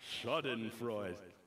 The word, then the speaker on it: Schadenfreude: Schadenfreude. Congrats, you all speak German. We should have schnitzels together sometime. But here's what Schadenfreude means.